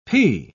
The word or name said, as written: pee